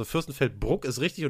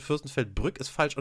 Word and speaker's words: Fürstenfeldbruck: ein paar Mal tatsächlich falsch ausgesprochen und Fürstenfeldbrück gesagt. So, so rum, ne? Also Fürstenfeldbruck ist richtig und Fürstenfeldbrück ist falsch.